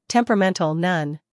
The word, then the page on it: temperamental: ˌtemprəˈmentl